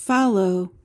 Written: fallow

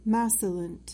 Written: macilent

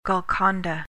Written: golconda

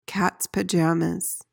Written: cats pajamas

